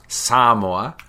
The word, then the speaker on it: Samoa: Cook Islands, it's all in that area with Tonga, Samoa, which is actually apparently pronounced Samoa.